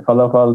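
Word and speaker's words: falafel: E, emin değilim onu bilmiyorum sormadım ama e, felafeli sevdiğini biliyorum yani. Ama şeyin farkında değil belki. Felafel onlarla geldi. Yani e, falafal diye de telaffuz ediyorlar. Bilmiyorum artık hangisi orijinal şeklidir.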